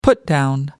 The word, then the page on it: put down: American English